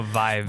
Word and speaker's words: Vive: When Oculus Rift, yeah, the Hive, yeah, is that what it's called? – No, Vive. – The Vive.